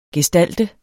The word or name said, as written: gestalt